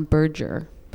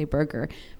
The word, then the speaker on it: Berger: I always heard his name pronounced John Berger. But people say burger.